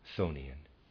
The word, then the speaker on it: Chthonian: Readers aside, Thonian is spelled C-H-T-H-O-N-I-A-N, Thonian, end of readers aside.